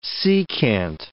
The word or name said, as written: secant